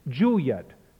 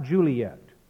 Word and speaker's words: Juliet: In his opening speech, he clearly uses the two-syllable pronunciation of the heroine's name. Notice he says Juliet, not Juliet.